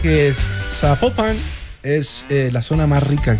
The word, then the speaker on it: Zapopan: Oiga, nos escriben los oyentes y que no se pronuncia Zapopan, sino que se pronuncia Zapopan.